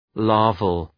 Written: larval

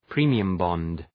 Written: premium bond

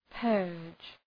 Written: purge

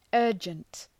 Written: urgent

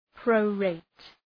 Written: prorate

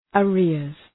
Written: arrears

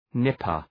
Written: nipper

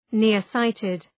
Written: near-sighted

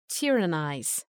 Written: tyrannize

